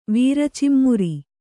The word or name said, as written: ವೀರಚಿಮ್ಮುರಿ